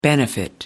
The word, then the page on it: benefit: British English